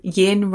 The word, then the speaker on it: yin: So something that, the way that I remember it is yin rhymes with dim.